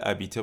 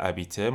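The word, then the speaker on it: habiter: درسته که با اش شروع میشه فعل ابیته منتها چون اش خب تلفظ نمیشه مثل این میمونه که انگار فعل ابیته با حرف وایل شروع شده باشه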